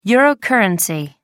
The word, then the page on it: eurocurrency: American English